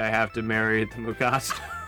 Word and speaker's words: Mugato: And behind door number three, I hope I'm pronouncing it right, the Mugato. – Yep. Mugato, yes.